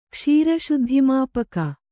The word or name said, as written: ಕ್ಷೀರಶುದ್ಧಿಮಾಪಕ